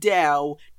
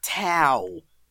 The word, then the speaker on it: Tao: And yes, it's pronounced Tao, not Tao.